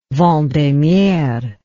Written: vendemiaire

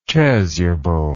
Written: chasubled

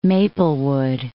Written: Maplewood

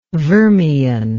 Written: vermian

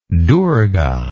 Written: durga

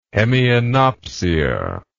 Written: hemianopsia

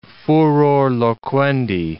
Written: furor loquendi

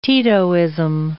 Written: Titoism

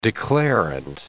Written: declarant

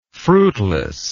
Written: fruitlessness

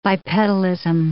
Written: bipedalism

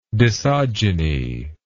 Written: dissogeny

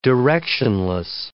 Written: directionless

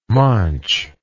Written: manche

